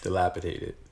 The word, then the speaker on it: dilapidated: You said tated, and it's dated. – Dilapidated. – D versus T.